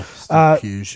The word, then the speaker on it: Pugh: Steve Pug, P-U-G-H, Pug? – Oh, Pug? – Yeah. Puge. – Puge, my bad.